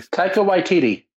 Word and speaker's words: Taika Waititi: All right, it's like Takiti. I can never say his name. Anybody? – Taika Waititi. – There you go.